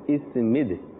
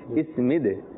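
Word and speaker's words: اثمد: اس مد، یہ درست تلفظ ہے اس مد،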